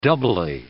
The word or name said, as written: doubly